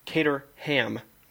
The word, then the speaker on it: Caterham: One open seat, which Wait, what's the team called again? – Caterham. – Ooh, is that how you say it? Really? – Caterham. – Okay. – What do you say, Caterham? – You're gonna go with Caterham? – Caterham.